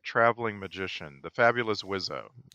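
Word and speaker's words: Wiseau: By the way, I just looked it up, and Tommy insists that we pronounce his last name Wizzo.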